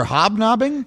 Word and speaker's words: hobnobbing: Were you looking for hobnobbing? – Correct.